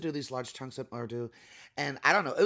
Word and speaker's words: Urdu: And your play has large chunks of it in Urdu. Am I saying it right? – Yeah, Urdu.